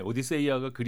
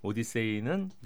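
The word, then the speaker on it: Odyssey: 그러니까 주인공은 오디세우스고 작품 이름은 오디세이, 오디세이아 이렇게 되는데 오디세이아가 그리스 원어에 충실한 발음이라면 오디세이는 영어식 발음이다 이렇게 보시면 되겠습니다.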